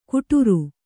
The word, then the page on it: ಘುಟುರು: Kannada